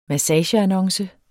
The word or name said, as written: massageannonce